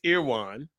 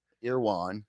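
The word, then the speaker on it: Erewhon: And it's pronounced what? – It's pronounced Irwan. – Irwan.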